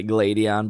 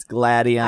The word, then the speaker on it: Gladion: I think a lot of people say Gladion, but it's Gladion.